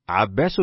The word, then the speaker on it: عباس: ترجمه: عباس بن الوليد وعياش بن الوليد، وهذان شيخان مشتبهان في الاسم خطأ، مختلفان نطقا، متفقان في الأب خطا ونطقا، مختلفان شخصا، فالأول بالباء الموحدة والسين المهملة، والثاني بالياء المثنة تحت والشين المعجمة، وقد أوضحت أمرهما في الفصل الماضي فليراجع منه.